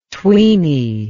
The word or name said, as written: tweeny